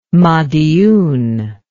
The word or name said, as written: madiun